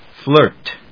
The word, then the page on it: Flirt: flˈɚːt ｜ flˈəːt